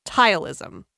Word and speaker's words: ptyalism: Okay, it's P-T-Y-A-L-I-S-M, tileism, okay? – It has two T's at the beginning? – No. P-T. P-T-Y-A-L-I-S-M. – P-T? Like pterodactyl? – Like pterodactyl. P-T-Y-A-L-I-S-M.